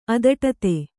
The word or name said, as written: ಅದಟತೆ